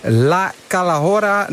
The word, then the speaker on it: La Calahorra: Het is een loodzware bergetappe van bijna 187 kilometer van, moet ik het in mijn beste Spaans zeggen, La Calahorra na Collado del Alguacil. Toch, wielercommentator Andries Lamain? – Ja, toch, als in dat ik eventjes die uitspraak beoordeel. Nou, helemaal goed, helemaal goed.